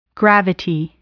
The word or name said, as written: gravity